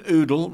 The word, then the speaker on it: Oodle: Uh, Colin Oodle rhymes with noodle.